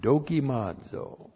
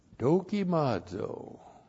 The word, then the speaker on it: dokimazo: And then we have this neat verb, dokimazo. Dokimazo. D-O-K-I-M-A-Z, long O. Dokimazo.